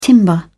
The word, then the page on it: timber: British English